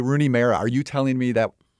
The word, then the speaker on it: Rooney Mara: In direct response to the email we read from Lisa Iannucci last week in this very segment, chastising us for our pronunciation, our incorrect pronunciation of the actress Rooney Mara, who she says is correctly Rooney Mara.